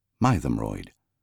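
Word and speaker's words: Mytholmroyd: The first syllable is pronounced as in my, not as in myth, Mythomroyd.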